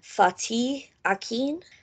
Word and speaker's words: Fatih Akin: But I think you pronounce the director's name Fatih Akin? – Yeah.